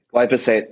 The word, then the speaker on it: glyphosate: glyphosate am i saying that right is it glyphosate glyphosate yep